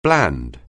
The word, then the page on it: bland: anglais